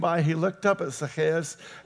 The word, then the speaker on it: Zacchaeus: There was a man there named Zacchaeus. That's the actual proper pronunciation of it. You'll hear it all the time pronounced Zacchaeus.